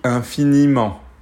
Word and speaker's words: infiniment: So if I break it down, the, the, the pronunciation of infiniment, that's ni ment.